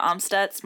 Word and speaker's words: Amstutz: And you can find me on all social platforms at Sandra Amstutz.